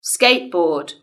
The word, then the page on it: skateboard: British English